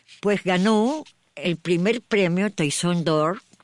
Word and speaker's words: Toisón de Oro: No sé cómo se pronuncia bien. – Toisón de Oro.